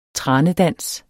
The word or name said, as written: tranedans